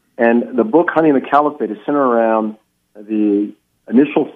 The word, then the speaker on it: Caliphate: Am I pronouncing that correctly, Caliphate? – You are.